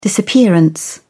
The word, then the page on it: disappearance: British English